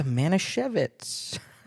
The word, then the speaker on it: Manischewitz: Anyway, we've got the Manischewitz. It's spelled with a W, but I think it's supposed to be a V sound. Is that fair? Otherwise, it'd be Manischewitz, which that doesn't sound right.